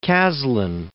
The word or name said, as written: Caslon